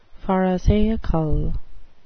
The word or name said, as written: pharisaical